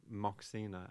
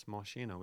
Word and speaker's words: Moshina: I was pronouncing it Moxina, as like English readers want to do, but it's Moshina, which is like a Taiwanese word for like a mountain spirit.